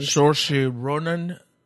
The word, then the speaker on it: Saoirse Ronan: Saoirse Ronan? – Yes.